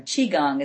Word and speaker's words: Qigong: Chi Chong. I don't know how to say that. And they also, some of the ladies went to a divine straightener. Qigong is how you say it.